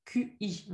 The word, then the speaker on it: qi: Bon, c'est qi, ça s'écrit Q-I, on est d'accord? – Oui. – Mais ça se prononce qi.